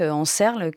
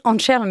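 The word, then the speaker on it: Ančerl: J'ai mal prononcé le nom de famille, Karel Anscherl, donc en Serle, qu'il doit être prononcé Anscherl.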